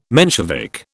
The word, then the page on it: Menshevik: British English